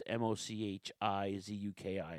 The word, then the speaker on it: Mochizuki: And Timothy Thatcher in a singles match versus Masaki Muchizuki. Muchizuki, very nice. It's Muchizuki, right. Or Makazuki. It's Mochizuki.